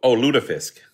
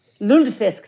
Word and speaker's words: lutefisk: Lutefisk. I can never pronounce it, the fish. – Oh, Lutefisk. – Lutefisk, thank you very much.